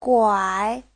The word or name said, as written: guai3